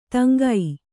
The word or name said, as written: ತಂಗು